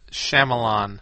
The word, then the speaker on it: Shyamalan: How do you say it? – Shyamalan. – Shyamalan, who's done some really good.